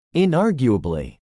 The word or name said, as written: inarguably